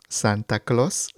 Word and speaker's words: Santa Claus: Es muy similar a la pronunciación del inglés, sí, cuando escuchamos a Santa Claus, pero eh, con un sonido de O, oh, Santa Claus, Santa Claus.